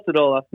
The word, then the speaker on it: Stodola: Phil Stodola, no big deal.